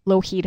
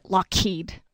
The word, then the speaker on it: Lougheed: Like here in the Lower Mainland, we have Lougheed Highway, and it always pronounces it Lockheed.